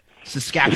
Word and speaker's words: Saskatchewan: That's how the locals say it. Saskatchewan. – Saskatchewan? So not Saskatchewan. Saskatchewan. – Yeah. It's like Toronto. Saskatchewan.